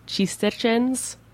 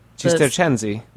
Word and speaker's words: Cistercensi: So I'm always terrible at pronouncing the name of the monastery that Giampiero Bea works with, the Monastero Suore Cis- Cistercians. – Cistercensi.